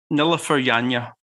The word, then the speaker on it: Nilüfer Yanya: I'm not 100% confident, but I'll go with Nilüfer Yanya. – Yeah, there you go. Sore throat's not helping me there. Nilüfer Yanya, and the track is called Midnight Sun.